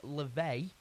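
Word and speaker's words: Laufey: We've got some LaVey, which I've been told it's pronounced, the new one of Mad Woman.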